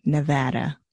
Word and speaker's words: Nevada: I mean, it's still kind of everywhere here in Nevada, too. Ooh, I said that wrong. Nevada.